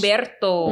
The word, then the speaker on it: Umberto: Umberto. How do you say that in Spanish? – Umberto.